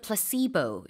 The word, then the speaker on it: placebo: 그래서 그거를 이제 우리는 뭐 플라세보라고도 하는데 영어로는 placebo 이렇게 발음을 하고요.